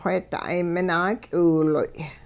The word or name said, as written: wheeda'ay minaak'iwiloy